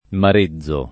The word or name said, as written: marezzo